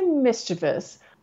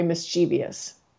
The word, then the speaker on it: mischievous: I have two pronunciations, and if I'm honest about it, if you give me that sentence, I'm going to say the puppy is very mischievous. Now, I'm aware that we might have some listeners right now who are thinking, wait a minute, that pronunciation isn't right. We may have some other listeners who are thinking, yeah, that's how I say the word.